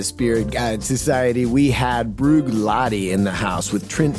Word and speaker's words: Bruichladdich: But just for our purposes, it is Bruichladdich. So this first CH is kind of like a K, and the second CH is silent. And Bruichladdich is a single malt distillery on the island of Islay, I-S-L-A-Y, Islay.